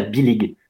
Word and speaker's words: bilig: Par contre, le beau cadeau à faire à un breton, c'est ce qu'on appelle la bilig. Ça s'écrit B-I-L-I-G.